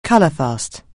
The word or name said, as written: colourfast